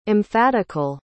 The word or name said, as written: emphatical